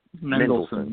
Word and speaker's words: Mendelsohn: Mendelsohn, yeah.